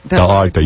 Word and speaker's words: dell'Arte: Trattoria dell'Arces. I don't know how to say it. Trattoria dell'Arces. – Dell'Arte.